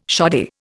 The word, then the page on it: shoddy: Indian